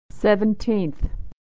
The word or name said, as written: seventeenth